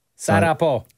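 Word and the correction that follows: Sagapo (σ' αγαπώ): Sagapo. Like Sagapo.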